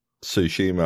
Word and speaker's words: Tsushima: Yeah, that was the other thing I did last week while I was playing Ghosts of Tsushima. I was... – You said it right! – The word! It's like sushi. – That silent T fucks me up every time.